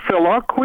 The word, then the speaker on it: filioque: Is it pronounced filioque or filioque? – Filioque. – That's just filioque. – Filioque is a proper pronunciation.